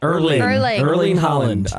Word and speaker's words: Erling Haaland: I don't know how to say it's Erling Haaland.